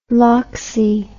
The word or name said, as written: loxie